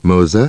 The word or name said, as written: Moser